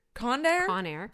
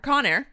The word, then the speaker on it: Conair: The airline Conair? – Conair. – Conair. That's how you say it.